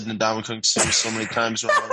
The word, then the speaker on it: Ndamukong Suh: I'm going to say it right. Ndamukong Suh. Ndamukong Suh. Ndamukong Suh. It's easy. Ndamukong Suh. There we go.